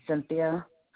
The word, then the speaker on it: Cynthia: It's Cynthia.